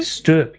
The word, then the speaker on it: stoep: Stoop. Yes. Is it really? S-T-O-E-P, which is pronounced stoop.